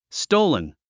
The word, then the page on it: stolen: stóulən; ストゥルン